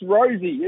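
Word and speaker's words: Rozee: It's Rosie. It's Rosie.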